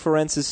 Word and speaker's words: Ferentz: Oh, are you following this whole Kirk Ferentz's son getting hired thing? – No.